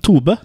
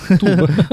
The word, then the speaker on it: Tobe: Litt usikker på hvordan man uttaler uh, fornavnet hans, egentlig. Ja, det Tobe. Tobe.